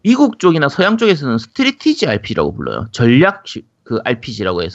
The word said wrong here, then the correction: strategy: 아, strategy.